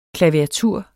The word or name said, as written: klaviatur